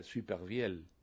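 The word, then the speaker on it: Supervielle: Superviel, que pronunciado en el origen sería superviel.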